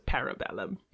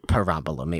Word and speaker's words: Parabellum: Yeah, so we're like, is it Parabellum? Is it Parabellum? and then he does say it in the movie, and it's Parabellum. – It's Parabellum. It will always be Parabellum.